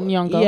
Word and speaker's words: Nyong'o: Nyong'o. – Okay. – She was in 12 Years a Slave. – Right. Right. Lupita Nyong'o.